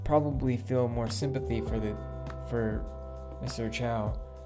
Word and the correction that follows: Chew: s- sorry, Mr. Chew.